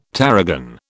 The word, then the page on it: tarragon: British English